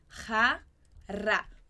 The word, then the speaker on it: jarra: Muito cuidado com a pronúncia, que você já viu também em aula de pronúncia do J, né? Então, o J sempre ra e o RR ra. Jarra. Jarra. É como se a gente estivesse falando a palavrinha rara em português, né?